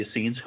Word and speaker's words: Essenes: The Essenes? – The Essenes.